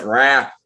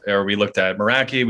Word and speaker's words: Meraki: But you probably pronounce it Rack. What would be the Boston thing? Rock. – Not how you pronounced it. Meraki.